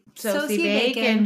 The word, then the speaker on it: Sosie Bacon: Is it Saoirse? – Sosie Bacon.